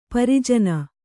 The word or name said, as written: ಪ್ರಜನ